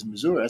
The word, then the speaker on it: Missouri: She said, "No, the proper way to say it is Missouri."